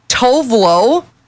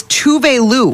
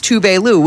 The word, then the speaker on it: Tuvalu: The big one, Tovlo. Is Tuvalu.